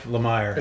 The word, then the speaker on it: Lemire: Jeff Lemire. – Is it Lemire? I don't know how you pronounce his name, but... It is.